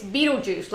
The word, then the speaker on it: Betelgeuse: It's Betelgeuse, like a beetle and a cup of juice.